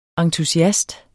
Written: entusiast

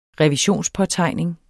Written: revisionspåtegning

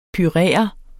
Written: purere

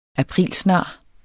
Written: aprilsnar

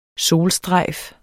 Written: solstrejf

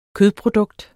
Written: kødprodukt

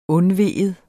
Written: undvige

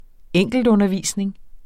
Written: enkeltundervisning

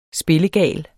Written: spillegal

